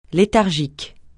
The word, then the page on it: léthargique: francais